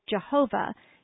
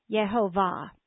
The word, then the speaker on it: Yehovah: In English, we have changed the Y to a J and accented the second instead of the third syllable, so we say Jehovah instead of Yehovah. From now on, however, I'm going to try to remember to pronounce the name of the Lord in the Hebrew way, Yehovah.